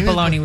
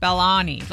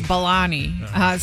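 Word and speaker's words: Belloni: Wait, it's not smell like the bologna we eat. – His name is Bologna? – Well, it's B-E-L-L-O-N-I. Maybe I said it wrong.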